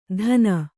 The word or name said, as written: ಧನ